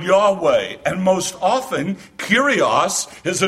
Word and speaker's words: Yahweh: And 6,700 of the 8,000 usages are a translation of the Hebrew which we pronounce Yahweh.